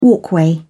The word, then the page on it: walkway: British English